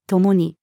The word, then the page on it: 共に: female